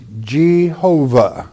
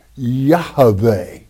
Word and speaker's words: Yahweh: For example, many would say Jehovah. Well, that's incorrect. It's Yahweh, okay, to be properly pronounced and spelled from the Hebrew manuscripts.